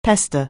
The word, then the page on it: pester: anglais